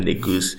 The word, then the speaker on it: Negus: So one of the pronunciations I came across was the Negus. – Negus. – Yes. – I mean, that sounds less like the N-word, so let's go with Negus.